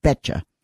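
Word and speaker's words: betjak: First, they paid the betcha, B for boy, E-T-J-A-K, pronounced betcha.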